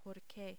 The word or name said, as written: porque